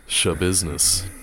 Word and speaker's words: Schabusiness: It's spelled S-C-H-A business. Shabusiness.